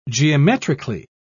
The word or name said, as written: geometrically